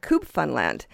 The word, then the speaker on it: Cube Funland: We've been enjoying their music since their first album and all the ones since, including their latest, their fifth, and that's called Cube Funland. Now, that sort of rhymes with Newfoundland,